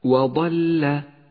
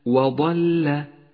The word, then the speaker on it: وضل: اقرأ الآن كلمة وضل وضل، ولاحظ أن حرف الضاد حرف مفخم توسط بين حرفين مرققين الواو واللام، فلا تخلط بين ترقيقهما وتفخيمه، أي هكذا وضل وضل.